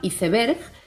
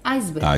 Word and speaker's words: iceberg: En España se pronuncia leyéndolo tal cual, iceberg. Y en América se pronuncia reproduciendo la pronunciación del inglés, iceberg.